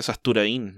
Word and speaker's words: Sasturain: Sasturain, creo que se pronuncia Sasturain, no, o sea, con tilde en la I.